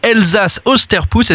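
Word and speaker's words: Elsass-Osterputz: Lumière sur un événement pas facile à prononcer lorsqu'on n'est pas alsacien, c'est Elsass-Osterpuss, est-ce que je prononce correctement ? – C'est très bien, oui.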